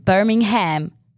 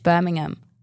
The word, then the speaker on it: Birmingham: Du sagst auch nicht, Birmingham. Es ist Birmingham. Das ist Aussprache.